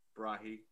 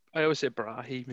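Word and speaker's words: Brighi: Matteo Brahi getting the other. I never know how to say his name, eh? Briggy. – Yeah. I always say Brahi, me.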